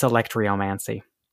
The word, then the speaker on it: Alectryomancy: It's Alectryomancy.